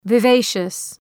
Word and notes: vivacious: vı’veıʃəs

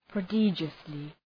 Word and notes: prodigiously: prə'dıdʒəslı

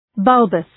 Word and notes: bulbous: ‘bʌlbəs

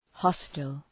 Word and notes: hostel: ‘hɒstəl

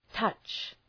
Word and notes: touch: tʌtʃ

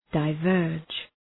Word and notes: diverge: dı’vɜ:rdʒ

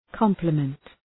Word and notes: compliment: ‘kɒmpləmənt

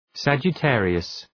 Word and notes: Sagittarius: ,sædʒı’terıəs

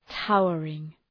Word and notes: towering: ‘taʋərıŋ

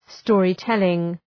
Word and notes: storytelling: ‘stɔ:rı,telıŋ

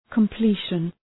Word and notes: completion: kəm’pli:ʃən

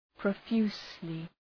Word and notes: profusely: prə’fju:slı

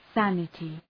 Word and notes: sanity: ‘sænətı

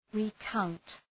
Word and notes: recount: ri:’kaʋnt

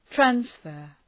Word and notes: transfer: træns’fɜ:r

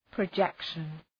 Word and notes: projection: prə’dʒekʃən

